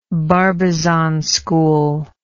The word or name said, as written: barbizon school